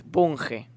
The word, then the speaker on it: Bunge: bueno, o sea que tengo un amigo que me dice, acá no es ingeniero Budge, es Bunge.